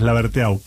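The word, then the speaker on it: Laberteaux: Ken Laverteaux, dice. – Me gusta cómo pronunciar. – Porque es francés, es Laverteaux.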